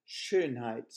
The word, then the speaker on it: Schönheit: Schönheit is pronounced as if it was Shane Height. And you make an ooh lip when you say Shane. So it's Schönheit.